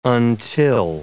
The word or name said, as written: until